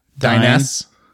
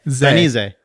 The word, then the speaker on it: Dainese: Dynes. – Dine. Ease. No. – Die. – Die. – Knee. – Knee. Dainese. – Zay. – Dainese.